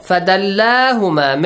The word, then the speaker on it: فَدَلَّاهُمَا: So, فَدَلَّاهُمَا, make sure you pronounce the alif there, بِغُرُور.